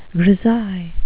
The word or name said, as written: grisaille